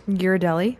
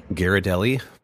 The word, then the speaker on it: Ghirardelli: Ghirardelli. – Ghirardelli.